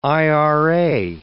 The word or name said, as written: Ira